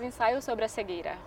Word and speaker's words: Ensaio sobre a cegueira: How do you say it in Portuguese is Ensaio sobre a cegueira.